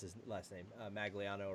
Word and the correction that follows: Manganiello: Or Joe Manganiello? – Manganiello, whatever.